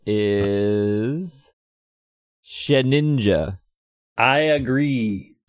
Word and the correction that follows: Shedinja: Well, except properly pronounced, it's Shed Inja, Shedinja. There's a D in there.